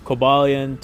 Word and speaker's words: Cobalion: Cobalion, Terrakion, and Virizion.